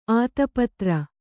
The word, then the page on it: ಆತಪತ್ರ: ātapatra